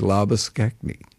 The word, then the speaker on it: Labuschagne: But the correct pronunciation seemingly is Labuschagne.